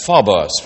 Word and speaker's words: phobos: If you'll forgive the Greek, it's pronounced phobos, from which we do get phobia.